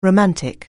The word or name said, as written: romantic